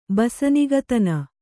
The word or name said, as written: ಬಸನಿಗತನ